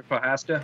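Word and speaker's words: Procházka: Jerry Prochazka.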